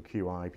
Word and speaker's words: Qi: It's all standard technology, which the principle of wireless charging standard is called Qi, or QI, people call it, but it's pronounced Qi.